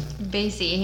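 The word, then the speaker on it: Marc E. Bassy: So it's Marky Bassey? – Bassey. He made us say his name like a dozen times.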